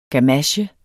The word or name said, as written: gamache